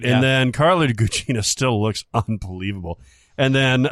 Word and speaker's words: Carla Gugino: And it's just, Carla Gugino is that her name? – Yeah, that's how I pronounce okay.